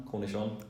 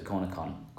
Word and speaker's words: cornichon: How do you say it? Cornichon? – Cornichon. – I thought it was cornicon.